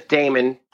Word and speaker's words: Damon: Um, my my my name is Damon, so it's spelled D A M O N.